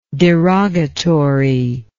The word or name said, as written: derogatoriness